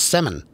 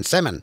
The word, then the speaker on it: salmon: The L in the word salmon is silent. I've heard it called salmon. But it's salmon. L's shouldn't be there. It's just there for decoration. Salmon.